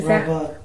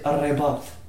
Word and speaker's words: Rabat: How do you say that? Yeah. Am I saying it right? Rabat. – Is Rabat. – That, now, is that, tell me about the culture there real quick. – In Arabic, you said Arrebat.